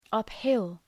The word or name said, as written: uphill